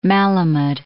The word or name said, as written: Malamud